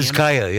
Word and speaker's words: Vizcaya: So correct my pronunciation if, I, right. I'm, in, if I'm incorrect. The Vizcaya Museum. Vizcaya, yeah.